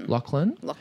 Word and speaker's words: Loughlin: Loughlin.